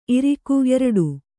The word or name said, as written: ಇರಿಕೆ